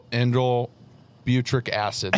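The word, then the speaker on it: indole butyric acid: And so the first thing is that indole butric acid.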